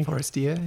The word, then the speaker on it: Forestier: Am I pronouncing the surname correctly? – Forestier, yeah, that's yeah.